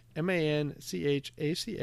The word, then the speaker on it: Manchaca: It, it's spelled M A N C H A C A, but pronounced Manchac.